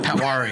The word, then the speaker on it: Patwari: Like, I don't even know if I know how to pronounce your last name properly. – Patwari. – Patwari. – Oh, I said Patwari. – No, you said it. – You said it right.